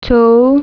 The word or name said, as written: مِه